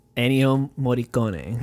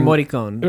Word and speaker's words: Morricone: Ennio Morricone. – Right. – And back in, or Morricone.